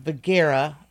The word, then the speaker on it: Vergara: Vergara. – This is just not my day for that's pronunciation. – Okay. That's okay. Really it's like Sophia.